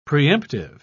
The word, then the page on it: preemptive: British English